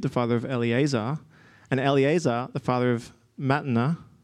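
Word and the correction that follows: Matthan: and Matan? How would you say that? Mathan? Oh, there's a TH there.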